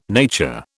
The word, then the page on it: nature: British English